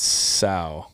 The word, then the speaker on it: Cao: How did you pronounce the cow? C-A-O. – It's sow.